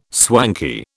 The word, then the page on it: swanky: British English